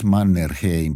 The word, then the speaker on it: Mannerheim: And and uh, well, we say of course Mannerheim, but he pronounced it in some way.